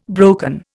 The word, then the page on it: broken: Indian